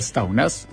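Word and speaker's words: Staunæs: Este es un partido que se creó en 2020 por Asker, y me van a perdonar como voy a pronunciar este apellido, pero... – Es como de lelo como suena. – ¿Staunas? – Okay. Asker Staunas.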